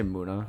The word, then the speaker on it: Muna: It's spelled M-U-N-A. – Say Muna. – Yeah. – I would say Muna, too.